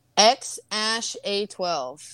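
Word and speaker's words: X Æ A-12: It's apparently pronounced X Ash A twelve.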